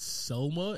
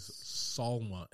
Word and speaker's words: Salma: It's not Selma. It's always Salma,